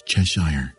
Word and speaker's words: Cheshire: And by the way, Joyce, it's Cheshire, not Cheshire.